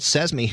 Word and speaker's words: Sezmi: So there's a company called SESME who's going with the old TV waves to offer you new TV. Silicon Valley company. It's spelled S-E-Z-M-I, like Open SESME, if you're phonetically challenged.